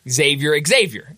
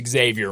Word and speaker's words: Xavier: And my buddy immediately texts me, "You pronounce Xavier wrong." I go, "I don't care. I do not care that I." He was like, "There's no E at the beginning of it," and I was like, "He's like, it's Xavier Musketeers."